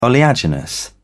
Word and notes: oleaginous: British English

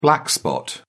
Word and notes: black spot: British English

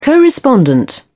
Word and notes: co-respondent: British English

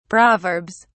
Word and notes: proverbs: British English

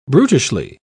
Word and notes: brutishly: British English

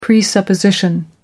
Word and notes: presupposition: American English

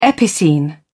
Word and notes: epicene: British English

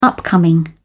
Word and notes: upcoming: British English

